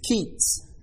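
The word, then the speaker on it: keets (קץ): The word for end used in this passage, when he says the end has come upon my people Israel, is keets, phonetically spelled K-E-E-T-Z.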